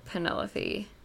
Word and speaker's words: Penelope: This last one is said by the Duchess to Penelope. Mm-hmm. Is that how you pronounce her name? – Mm-hmm. Yes.